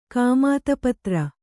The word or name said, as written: ಕಾಮಾತಪತ್ರ